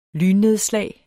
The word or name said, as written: lynnedslag